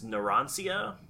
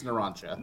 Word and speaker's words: Narancia: It's hard to tell where, like, the proper pronunciation ends and, like, the Japanese accent begins, but I think it's Narancia. – No, yeah. – It is because... – In Italian pronunciation, the C is often pronounced with a ch.